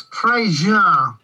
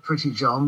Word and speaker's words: Prejean: Actually, it's Prejean. It's spelt as if you should say it Pretty John, but it's Norman, you know.